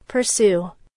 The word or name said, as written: pursue